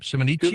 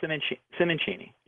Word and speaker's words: Simoncini: How does he pronounce his last name? Simonici? – Giulio Simoncini. Yes.